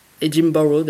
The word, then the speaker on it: Edinburgh: Le prix en jeu, car il y avait un prix, c'était le trophée yuk of Edinburgh Edinburgh d'ailleurs, je devrais le prononcer comme ça si je dis Yukov of Soyez Edinburgh.